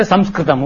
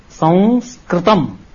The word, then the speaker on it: Sanskritam: It's a pure nasal sound. Sanskritam. But in South India, they say Sanskritam. Oh, chalta hai. But it is not technically perfect. Technically, if you want to say it correctly, Sanskritam.